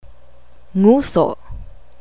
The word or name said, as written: nguso